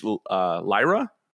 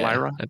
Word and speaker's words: Lyra: Epic Games has released Lyra. Did you pronounce that? – Yeah. – Lyra? L-Y-R-A.